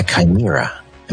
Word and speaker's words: chimera: When you take two different species and you create a new creature, a chimera. Am I pronouncing that correctly? I think so. – Yes, yes, that's it.